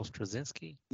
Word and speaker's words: Straczynski: Statisky. Oh, I don't know. – Uh, uh, we did the episode with Ghostbusters. – J. Michael Straczynski.